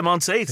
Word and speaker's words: Monteith: How do you pronounce it? is it Monteith? – Yeah, Monteith. Yeah, that's nice.